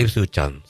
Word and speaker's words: Ipswich Town: Ipswich Town. – El Ipswich Town, sí.